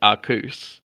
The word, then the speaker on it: Arceus: I, I'm not gonna bother saying it because I, I call it Arceus or Arceus, yeah. but yeah. apparently, and I don't know how true this is. Apparently, the proper pronunciation is Arcus and I hate that.